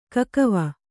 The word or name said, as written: ಕಾಕಿವೆ